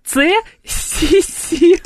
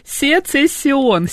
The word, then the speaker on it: Сецессион: Максим написал, извините, Цессион. А он пишется Сецессион. Сецессион, понимаете? – Он через Сецессион. Просто я говорю тоже Цессион. А он Сецессион.